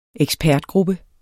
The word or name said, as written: EKSPERTGRUPPE